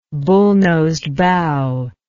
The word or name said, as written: bullnosed bow